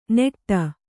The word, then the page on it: ನೆಟ್ಟ: neṭṭa